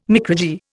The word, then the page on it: micrurgy: Indian